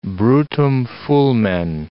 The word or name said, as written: brutum fulmen